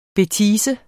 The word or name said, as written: BETISE